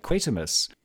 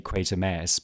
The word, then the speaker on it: Quatermass: And note the pronunciation, everyone. I have egg on my face. I have been lambasting my poor American friends for mispronouncing Quatermass's name. Now, about 50% of the time in this film, they do say Quatermass, but they also say Quatermass.